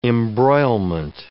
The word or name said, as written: embroilment